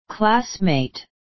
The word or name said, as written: classmate